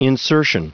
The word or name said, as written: insertion